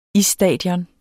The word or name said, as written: isstadion